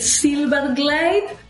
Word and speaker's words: Zylberglait: Zylberglait, but easier, Dr. C or Miriam, whatever is good.